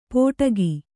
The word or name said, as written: ಪೋಟಗಿ